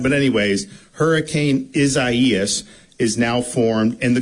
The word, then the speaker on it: Isaias: We're here at the new Division of Emergency Management Emergency Operations Center with preparations undergoing for now Hurricane Isaias. The pronunciation is a little bit weird. I've told people if they want to do Isaiah, that's fine with me.